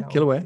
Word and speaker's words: Kilauea: Yeah, Kilauea.